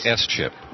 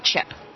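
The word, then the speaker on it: SCHIP: And the acronym, do you say CHIP, or is the S, do you say S-CHIP? – Well, we say CHIP. – Oh, the S is silent.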